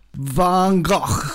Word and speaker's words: Van Gogh: So it's Van Gogh. – And that's really, or Van Gogh. There's no, the actual G isn't really pronounced. It's more like a GH. So it's Van Gogh, something like that.